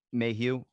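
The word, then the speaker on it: Maheu: So this was a memorandum from Director of Security, subject Roselli and the Mahu matter. I probably saying that name wrong. – Mayhew.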